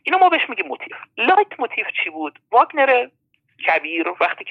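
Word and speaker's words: Leitmotiv: لایت موتیف، البته من جایی دیدم خیلی بعد اینو تلفظ میکنم میگن موتیف. موتیف کلمه انگلیسیه به معنی انگیزه که اصلا خیلی فرق میکنه. این موتیف کلمه آلمانیه به معنی شبه جمله است.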